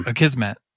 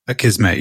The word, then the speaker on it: Akismet: I looked at a couple of people recommended something called Askimet or Askimet. I don't know how to pronounce it, which is some... – Oh, Akismet. – Akismet, yeah.